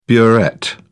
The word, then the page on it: burette: British English